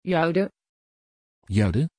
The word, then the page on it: jude: Dutch